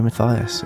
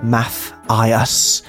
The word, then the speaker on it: Mathias: Gary Mathias, and you've written it phonetically there. Math, I, us.